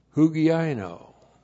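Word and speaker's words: Hugiaino (ὑγιαίνω): And then, hugiaino. H-U-G-I-A-I-N, long O. Hugiaino.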